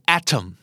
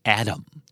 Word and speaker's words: atom: atom นะครับตัวทีชัดเจนกว่านี้หน่อยแต่ว่าถ้าเกิดเป็นอเมริกัน accent อ่านว่า Adam